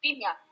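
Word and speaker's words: Silja: Es que la J se pronuncia como I acá. Xilia. Xilia, sí.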